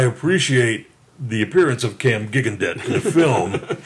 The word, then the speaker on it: Gigandet: Gigandet. I think you know how to pronounce his last name. – Gigandet.